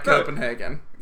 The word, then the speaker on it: Copenhagen: Are you sure you don't want to take another run at Copenhagen? – Copenhagen. – Copenhagen. – Is that? – Yeah, that's, no, you got it. – Copenhagen.